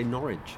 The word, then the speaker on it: Norwich: is pronounced to rhyme with porridge. – Norwich? – Norwich. People from Norwich, Norwich, say Norwich.